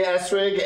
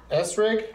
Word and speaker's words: Essrig: Bree Esrig and Esther Koo are here, everybody. – Thank you so much. – Did I pronounce that right? – Yeah. – Esrig? – Esrig, yeah. – E-S-S-R-I-G.